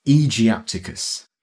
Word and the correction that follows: aegyptiacus: Well, you're the classicist, Aegyptiacus.